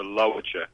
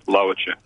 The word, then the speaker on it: Lawitja: The emphasis is on the... I'll just pronounce it. It's the Lawitja. The emphasis on the first syllable, Lawitja.